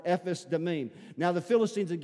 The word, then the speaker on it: Ephes: They were gathered together at Succoth, which belongeth to Judah, and pitched between Succoth and Azekah in Ephes, that's how you pronounce that, Ephes Damim.